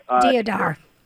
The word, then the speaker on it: Deodar: Is it Deodar? – Deodar.